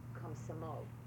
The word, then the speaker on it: Komsomol: As I fell in already, I was 13, so I was already, the name of the party is called Komsomol. – How would you spell that? – Komsomol.